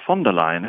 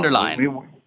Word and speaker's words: von der Leyen: Ursula von der Leyen. It's very easy. Trips off the tongue. Von der Leyen. Very good. – Von der Leyen, yeah. Exactly.